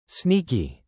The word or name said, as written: sneaky